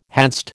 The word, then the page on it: hadst: American English